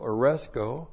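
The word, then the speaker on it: aresko (ἀρέσκω): First of all, the one we have in our verse, aresko, A-R-E-S-K, long O, aresko.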